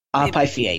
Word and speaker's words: Pythia: Pythia, yes.